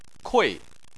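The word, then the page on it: kui: tone 4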